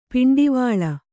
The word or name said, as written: ಪಿಂಡಿವಳ